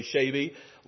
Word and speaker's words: Shaby: They pronounced my name the wrong way. It's really Shaby, long A.